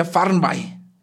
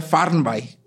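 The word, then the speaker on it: Fernweh: En realidad se pronuncia Farnby. Es una palabra alemana y se pronuncia Farnby.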